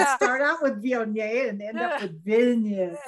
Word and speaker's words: Viognier: You did well to say mm. Viognier. – I say it Viognier. I don't know. People say Vignier, Viognier. It depends on how many uh, syllables you want to put on the word.